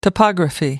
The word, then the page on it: topography: American English